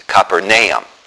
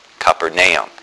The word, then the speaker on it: Capernaum: He always says we pronounce that wrong. It's Capernaum. Capernaum.